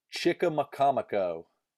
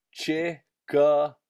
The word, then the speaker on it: Chickamaconico: Chickamaconico. Chickamaconico. – Pretty much just how it's spelt, so that's not that bad.